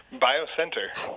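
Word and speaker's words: BioSNTR: How do you say that? – Biocenter. Bio oh, Biocenter. That makes sense.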